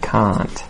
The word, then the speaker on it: can't: So if you're worried about making that mistake, just make sure that you elongate the vowel sound in the word can't. Okay? Don't make it quick. Can't.